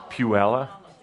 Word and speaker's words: Puyallup: Here from Puyallup, Washington. Whatever. – Good to see you. – How do you say it? – Puella.